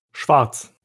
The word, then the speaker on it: Schwarz: How do you say that the German way? – Schwarz.